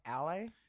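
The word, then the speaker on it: Aulë: I believe he's the servant of Aeol. The Valar in charge of crafting and smith work. – I think it's pronounced Ale.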